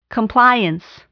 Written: compliance

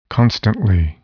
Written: constantly

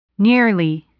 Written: nearly

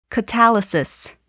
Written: catalysis

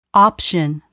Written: option

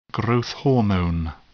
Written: growth hormone

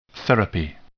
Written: therapy

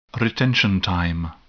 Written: retention time

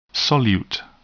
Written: solute

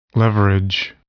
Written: leverage

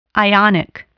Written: ionic

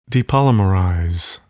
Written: depolymerize

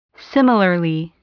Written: similarly

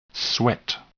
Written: sweat

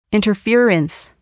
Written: interference

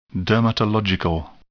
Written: dermatological

